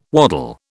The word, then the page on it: waddle: British English